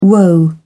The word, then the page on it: woe: British English